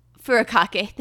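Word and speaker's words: furikake: I have some, how do you say Furukame? Furukame seasoning that has a Prop 65 warning on it. Now, I think Furukake, thank you.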